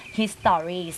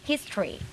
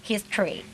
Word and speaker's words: history: เด็กไทยสมัยนี้จะออกเสียงอย่างเงี้ยค่ะตรงตัว history ซึ่งไม่ได้ค่ะต้องอ่านออกเสียงว่า history นะคะเด็กๆทางบ้านออกเสียงตามนะคะ history